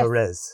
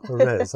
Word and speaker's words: Jerez: So we went to, I pronounce it Jerez. – Jerez. – Thank you, Jerez. I knew I'd get it wrong.